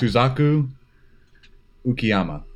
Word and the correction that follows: Shusaku Uchiyama: You agree with that pronunciation? Shuzaku? – Shusaku Uchiyama? Yeah, that's good.